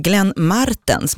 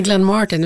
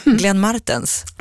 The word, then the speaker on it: Glenn Martens: Glenn Martens med betoning på R-et. Vem är det? – Ja, det är inte Glenn Martin. Utan Glenn Martens.